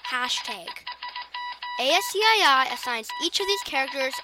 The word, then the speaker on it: ASCII: ASCII, pronounced ASCII, is the abbreviation for American Standard Code for Information Interchange.